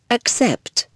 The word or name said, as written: accept